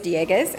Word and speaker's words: Dieguez: My last name is Dieguez, as you heard Ben say. Good pronunciation. Thank you, Ben.